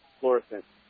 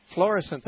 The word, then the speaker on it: Florissant: Let's talk to Florissant, Michigan. Is that how you pronounce it? – No, it's Florissant. – Florissant, thank you.